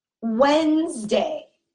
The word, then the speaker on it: Wednesday: now we have the day of the week Wednesday. Wednesday. It is spelled W E D N E S D A Y. And this first D is going to be silent. And it sounds like Wednesday.